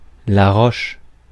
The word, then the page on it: roche: /ʁɔʃ/; /ʁoʃ/